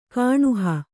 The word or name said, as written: ಕಾಣುಹ